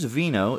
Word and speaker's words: wino: Although you're a wino, but it's W-I-N-O, but as we all know, in Polish, the W's are pronounced V's. So it is, in fact, Vino.